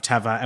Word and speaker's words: Teva: I've always pronounced it Teva, and then Anders, for the listeners to know, was like, actually, it's Teva. And so how would you describe the brand story of Teva?